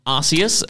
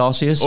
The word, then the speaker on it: Arceus: And we had Pokemon Arceus. – Very true. – Is that how you pronounce it? – Arceus? Honestly, Nintendo themselves have said Arceus and Arceus, okay. But I prefer Arceus.